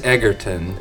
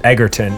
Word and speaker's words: Egerton: Elton John and Taron Egerton. Edgerton? I don't ever know how to say that properly. Mm. Are going to do... – Like, I think he's Egerton. – Egerton. And then the other guy, Joel Edgerton. We solved it.